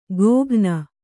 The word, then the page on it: ಗೋಘ್ನ: gōghna